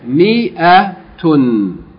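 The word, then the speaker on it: Mi-a-tun (مائة): This alif in mia is not pronounced. Okay? This is pronounced as though the alif is not there. Mi-a-tun. As though this alif is non-existent.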